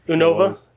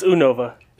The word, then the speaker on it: Unova: That's how it's said? – Unova? It's Unova.